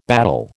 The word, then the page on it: battle: American English